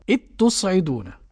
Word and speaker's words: إذ تصعدون: يقرأ المدغمون إذ تصعدون. – ماشي. يبقى النطق إيه؟ بتاء واحدة. – نعم. – مش بتائين كما يقول الآخرون، لا، بتاء واحدة مشددة.